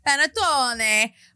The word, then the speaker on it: Panettone: They sent me this gorgeous, how do you say it? Panettone? – Yeah. – Panettone, which is basically like a ginormous pastry cake.